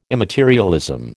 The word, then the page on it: immaterialism: American English